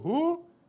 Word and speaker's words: Hur: And Moses, Aaron, and Hur, because it's Herb, I'm pronouncing Hebrew, Hur, went up to the top of the hill.